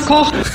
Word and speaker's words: Koch: Auch schwierig, die ehemalige deutsche Bundeskanzlerin Angela Merkel, die der Name von Roland Koch falsch gesagt hat. – Lieber Roland Kotz Koch.